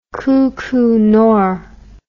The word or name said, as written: kuku nor